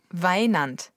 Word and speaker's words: Weinand: Weinand. Das schreibt man W-E-I-N-A-N-D.